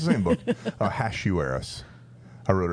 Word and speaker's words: Ahasuerus: Ahasuerus. – Yeah. – It's Hash. – Hash? – Not Hosh. Oh I looked gosh. It up. I have a book that shows me how to do this. Ahasuerus.